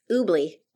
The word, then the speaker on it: Oobli: It's Oobli, O-U-B-L-I. Our name is inspired, but not spelled like it. And it's with a B, not a G.